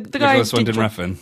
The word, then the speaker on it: Nicholas Winding Refn: Is it Nicholas Redfern Wunig? The Nicholas Winding Refn.